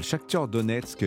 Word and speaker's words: Shakhtar Donetsk: Oui, et puis il y a le Shakhtar Donetsk euh, qui joue aussi.